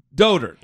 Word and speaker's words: dotard: Is that how you say it? – Dotard. – That's like the elegant way to say it.